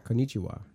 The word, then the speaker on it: konnichiwa: Konnichiwa. Is that how you say that? Or is there any kind of weird inflection or just konnichiwa? – Konnichiwa. – Konnichiwa.